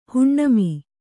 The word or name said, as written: ಹುಣ್ಣಮಿ